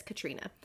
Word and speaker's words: Catriona: Okay, so she said, I wanted them to see Katrina, which apparently I know it's spelled Catriona, but I was told by an Irish listener that it's Katrina.